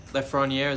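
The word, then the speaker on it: Lafrenière: And I think Toronto's in play for Alexis Lafreniere.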